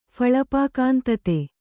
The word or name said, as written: ಫಳಪಾಕಾಂತತೆ